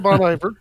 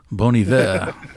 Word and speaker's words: Bon Iver: Not really Bon Iver, right? – you could call it Bon Iver. – But that's the nice French pronunciation. Bon Iver.